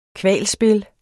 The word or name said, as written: kvalspil